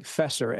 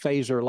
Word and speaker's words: Feser: When you see it, it looks like Fesser, F-E-S-E-R, but it's pronounced Fazer like the weapon in Star Trek.